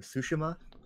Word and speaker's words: Tsushima: we're going with how they pronounce it in the game. Tsushima.